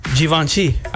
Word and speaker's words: Givenchy: Let's start with G-I-V-E-N-C-H-Y.